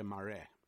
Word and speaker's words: Marais: Marais, uh, pronounced in South Africa Marais.